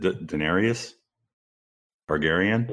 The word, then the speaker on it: Daenerys Targaryen: Let's go to, I'm going to mispronounce this. Is it Daenerys? Targaryen? – Daenerys Targaryen, yeah.